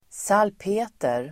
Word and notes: salpeter: [²s'al:pe:ter]

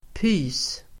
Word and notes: pys: [py:s]

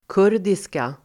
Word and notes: kurdiska: [k'ur:diska]

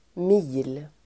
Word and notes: mil: [mi:l]

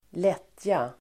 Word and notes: lättja: [²l'et:ja]

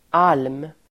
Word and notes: alm: [al:m]

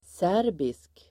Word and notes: serbisk: [s'är:bisk]

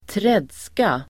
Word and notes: tredska: [²tr'ed:ska]

